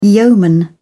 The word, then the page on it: yeoman: English; British English